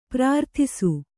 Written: ಪ್ರಾರ್ಥಿಸು

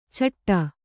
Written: ಚಿಟ್ಟು

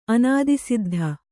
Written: ಅನಾದಿಸಿದ್ಧ

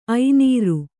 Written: ಐನೀರು

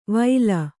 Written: ವೈಲ

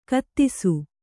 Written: ಕಥಿಸು

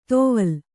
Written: ತೊವಲ್

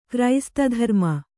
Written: ಕ್ರೈಸ್ತಧರ್ಮ

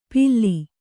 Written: ಪಿಲ್ಲಿ

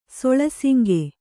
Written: ಸೊಳಸಿಂಗೆ

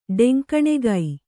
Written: ಡೆಂಕಣಿಕೈ

